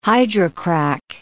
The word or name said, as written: hydrocrack